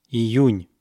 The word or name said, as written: iyun